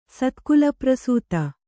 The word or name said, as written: ಸತ್ಕುಲಪ್ರಸೂತ